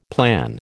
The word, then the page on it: plan: American English